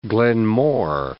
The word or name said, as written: Glen More